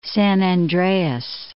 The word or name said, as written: San Andreas Fault